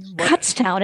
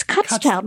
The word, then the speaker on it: Kutztown: Did I get that pronunciation right? – No, you did not. – Damn it. – Kutztown. It's Kutztown.